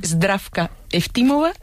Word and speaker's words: Zdravka Eftimova: A ver, Georgi Gospodinov, sí. Zdravka Eftimova y Vera Mutavcheva. – Ahora sí, ahora sí.